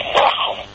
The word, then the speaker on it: Nahum: We have a common name, Nahum. If you look in Shrei Asar, there's a Navi. His name is Nahum, with a patach under the nun.